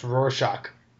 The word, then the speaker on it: Rorschach: I think uh, I think it's actually pronounced Rorschach. Thank you.